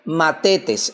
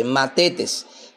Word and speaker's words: mathetes: Entonces, el término discípulo viene de una raíz obviamente griega que se dice, llama o se pronuncia o se dice Matetes, se escribe M-A-T-H-E-T-E-S, Matetes, que significa simplemente un pupilo o un alumno.